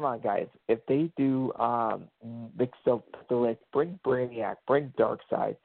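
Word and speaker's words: Mxyzptlk: Well, how do you pronounce it? – Mitsplik. That's how I say it the right way. Mitsplik.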